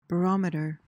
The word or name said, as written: barometer